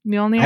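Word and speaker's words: Mjölnir: Mjolnir. I pronounce it Mjolnir. – Mjolnir? All right. Yeah. However people pronounce it. – Some people say Mjolnir. – Some people pronounce the J.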